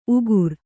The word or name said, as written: ಉಗುರ್